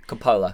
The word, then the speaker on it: Coppola: Coppola.